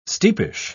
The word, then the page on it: steepish: British English